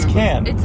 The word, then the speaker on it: Cannes: It's Cannes.